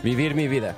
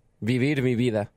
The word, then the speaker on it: Vivir Mi Vida: Vivid. – V-V-I-R. – Vivid. – Vivi? – Vivid. – Mi Vida. – Vivir Mi Vida.